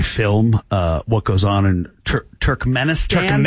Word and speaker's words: Turkmenistan: Turkmenistan. – Is that how you say it? Turkmenistan. – Okay.